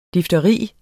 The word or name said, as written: difteri